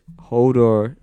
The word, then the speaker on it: Hodor: In the TV show, what was Hodor, did I pronounce that right? – Mm-hmm.